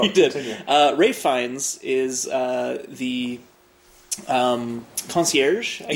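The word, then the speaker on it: Ralph Fiennes: Kind of a specific period of time during the hotel's life where Rafe Fiennes. – There you go. – I just found out, apparently, is how you pronounce it. – Yep. But his brother's name is still pronounced Joseph. – Not Jose. Yeah. – It's Joseph. – Why not just call yourself Ralph? – Because his name is Rafe. – God damn it. – I think it's Welsh. – It is Welsh. – Yes!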